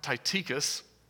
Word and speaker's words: Tychicus: And his name is pronounced Tychicus.